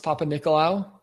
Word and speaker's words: Papanikolaou: Kostas Papanikolaou? – Yes.